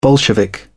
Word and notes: bolshevik: British English

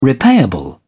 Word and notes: repayable: British English

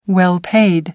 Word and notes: well paid: American English

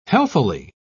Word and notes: healthily: British English

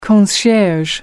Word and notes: concierge: American English

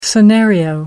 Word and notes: scenario: American English

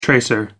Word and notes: tracer: American English

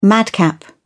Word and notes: madcap: British English

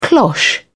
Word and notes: cloche: British English

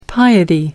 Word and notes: piety: American English

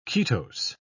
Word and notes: ketose: British English

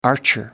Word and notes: archer: American English

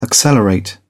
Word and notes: accelerate: British English

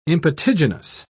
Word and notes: impetiginous: British English

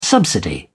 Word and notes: subsidy: British English

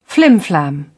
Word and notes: flimflam: British English

